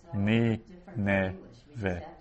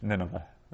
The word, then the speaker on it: Nineveh: And then the second syllable is n. So nun with a shva. And then the final syllable is ve. Ve. Ni, ne, ve.